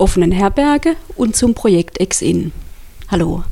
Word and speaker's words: EX-IN: Ex-In für die Abkürzung ist schon richtig ausgesprochen.